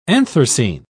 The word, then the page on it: anthracene: British English